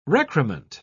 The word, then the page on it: recrement: British English